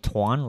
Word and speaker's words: Tuan: Tuan Pham. So can you can you spell that? – Like please? like okay, my my first name is Tuan, like T U A N, and my last name is Fam, like P H A M.